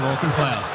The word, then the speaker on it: Auchenflower: Do you say Auchenflower? Is that how you say it? – Yeah, correct. Brisbane suburb, Auchenflower.